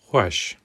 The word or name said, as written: xweş